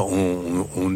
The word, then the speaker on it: ONG: On ne dit pas une ONG, on dit une ON. On ne prononce pas le G, on vous l'a dit tout à l'heure.